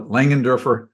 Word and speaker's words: Langenderfer: And you nailed it. Langenderfer, just like it's spelled.